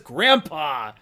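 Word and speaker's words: Grampá: I've literally listened to an interview with Frank Miller. He says Grandpa. I'm sure it's Grandpa.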